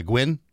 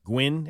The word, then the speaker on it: Nguyen: Uh, Dr. Quang, how do you say that last name? N G U Y E N? Is it? Uh, Gwyn. Gwyn? – Is Gwyn, that, is Gwyn. Gwyn. Gwyn.